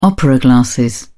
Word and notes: opera glasses: British English

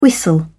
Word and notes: whistle: British English

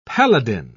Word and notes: paladin: British English

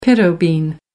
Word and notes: pinto bean: American English

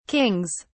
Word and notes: kings: British English

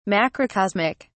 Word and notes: macrocosmic: British English

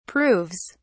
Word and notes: proves: British English

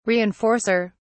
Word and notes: reinforcer: British English

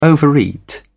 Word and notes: overeat: British English

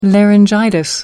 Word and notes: laryngitis: American English

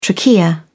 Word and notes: trachea: British English

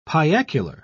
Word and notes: piacular: British English

